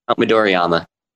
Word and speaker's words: Midoriyama: Midoriyama. Am I saying that correctly? – Yep, Midoriyama.